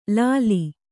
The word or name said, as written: ಲಾಲಿ